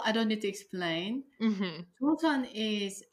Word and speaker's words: Joseon: But one really interesting book chapter of yours that I read was Hell Joseon. Is that how you pronounce it? Is that okay? – Yeah, Hell Joseon.